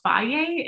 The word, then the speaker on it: Fage: And I think it's because that combination of the creamy and the protein, and the brand that I love is called Faye. It's spelled F-A-G-E.